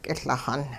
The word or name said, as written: k'iLixun